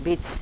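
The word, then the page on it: bic: dialetto croato di Lukezi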